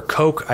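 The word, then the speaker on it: Koch: And then at eight, we have Charles and David Cook, or Coke. I think they pronounce it Coke, the Coke brothers.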